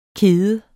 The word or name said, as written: kede